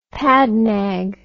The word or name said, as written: padnag